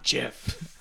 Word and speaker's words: GIF: James said it's not GIF. – I don't care. – It's a hard G. GIF stands for GIF.